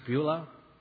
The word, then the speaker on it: Bilhah: Rachel, Rachel's maidservant, Bilhah, some pronounce Beulah, Leah, and Leah's maidservant, Zilpah.